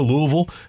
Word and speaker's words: Louisville: Then tonight, you've got the Holiday Bowl in San Diego, Louisville, and USC. Did I say it right? – You did, yes.